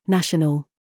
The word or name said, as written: TRAP